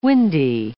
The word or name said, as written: windy